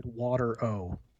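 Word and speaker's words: Water-O: everything takes place on is named Watero. – Okay. – Literally spelled water O, water hyphen O, but pronounced Watero.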